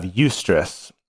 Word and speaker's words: eustress: And then by contrast, we have eustress. And eustress is spelled E-U, stress.